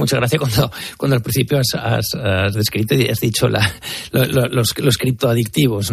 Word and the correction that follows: criptoactivos: ¿no? Me ha hecho mucha gracia. – Sí, porque lo he dicho mal. Era criptoactivos.